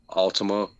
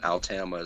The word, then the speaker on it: Altama: People, Altima, but Altama is I that's how they told me you pronounce it, because I kept calling them Altima. They were like, it's Altama.